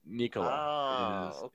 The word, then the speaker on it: Nikola: Well, you should say it like you would say Nikola Tesla, right? So that was the guy's name. So Nikola.